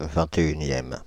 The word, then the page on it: vingt-et-unième: /vɛ̃.t‿e.y.n‿jɛm/